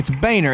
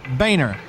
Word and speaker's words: Boehner: Ollie, again, it's Boehner. It's pronounced Boehner. – But it's spelled Boehner. – I know, Ollie, but it's not... Never mind.